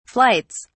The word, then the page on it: flights: British English